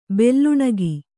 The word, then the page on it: ಬೆಲ್ಲುಣಿಗೆ: belluṇige